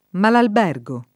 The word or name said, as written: Malalbergo